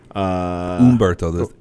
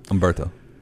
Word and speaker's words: Humberto: Humberto, H is silent, just so you know. Nope. Nope. There you go. – Carrillo. – Yeah, Humberto.